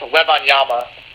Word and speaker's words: Wembanyama: Yeah, his last name is pronounced Wembanyama, by the way.